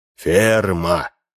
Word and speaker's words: Ферма: «Вам говорит что-нибудь имя фирма? Ферма. Ударение на последнем слоге».